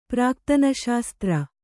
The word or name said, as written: ಪ್ರಾಕ್ತನಶಾಸ್ತ್ರ